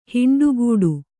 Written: ಹಿಂಡುಗೂಡು

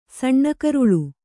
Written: ಸಣ್ಣಕರುಳು

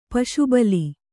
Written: ಪಶುಬಲಿ

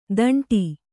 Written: ಥಂಡಿ

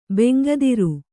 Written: ಬೆಂಗದಿರು

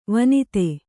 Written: ವನಧಿ

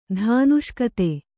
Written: ಧಾನುಷ್ಕತೆ

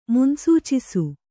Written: ಮುನ್ಸೂಚಿಸು

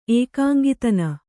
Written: ಏಕಾಂಗಿತನ

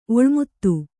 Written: ಒಳಮಾತು

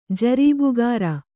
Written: ಜರೀಬುಗಾರ

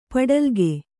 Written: ಪಡಲಗೆ

